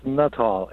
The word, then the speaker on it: Nuttall: It's Nuttall. N-U-T-T-A-L-L.